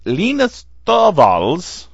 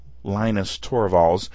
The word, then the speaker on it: Linus Torvalds: Linux began by Linus Torvalds. That's how he pronounces it. Linus Torvalds,